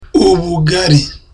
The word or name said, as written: ubugari